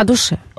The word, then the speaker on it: душе: Мэр Калининграда посоветовал думать о душе или о душе? – О душе. – О душе. – О душе. – Ударения нет.